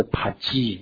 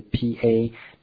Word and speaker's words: pagi: 那它不叫 fig，像我们平常吃到的这个无花果是 fig 啊 figs，那他们这边叫做 pagi 就是 pa-ge 这个是它发音，它叫 pagi。